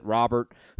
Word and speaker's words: Robert: He talked a lot about Lewis Robert, and that's how he said you pronounce it, Robert, who's 34% owned.